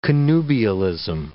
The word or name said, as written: connubialism